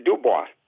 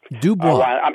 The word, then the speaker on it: Dubois: Dubois. – Dubois, Wyoming.